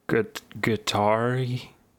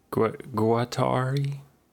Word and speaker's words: Guattari: I know how to pronounce Guattari. Guattari.